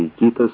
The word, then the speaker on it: Икитос: Я поначалу никак не могу привыкнуть правильно выговаривать название города Икитос с ударением на втором слоге, потому что для меня гораздо привычнее произносить Икитос.